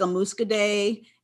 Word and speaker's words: Muscadet: If you ever had a Muscadet or Muscadet, not to be mistaken with Moscato, but something like a Muscadet. – Is that how you pronounce it? I think I've seen the spelling. I never knew. – Yeah. Got so Muscadet.